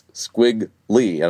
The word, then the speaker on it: squiggly: But when you add e to it, you don't end up with three syllables, squiggly. It's squiggly.